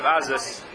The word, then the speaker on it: Azus: Can you phonetically spell that word, Azus? – Azus. A-Z-U-S.